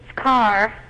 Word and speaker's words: Karr: Could you pronounce the last name for me? – It's Carr. – K-A-R-R. – Like an automobile? – Yeah.